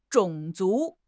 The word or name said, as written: 种族